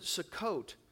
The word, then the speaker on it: Sukkot: And every year when they're celebrating the Feast of Tabernacles, they pronounce it Sukkot.